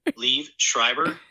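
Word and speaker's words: Liev Schreiber: Leave Schreiber. – Leave Schreiber. You're right. You're great at pronunciation. – Thank you. – You were right on the money.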